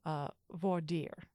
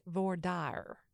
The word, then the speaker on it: voir dire: And many other places, it's pronounced close to the French original pronunciation. So you get voir dire. But in Texas, if you go to a Texas law school, we are all trained to pronounce it voir dire. – With a twang. – With the twang. Yes, dire is two syllables, not one.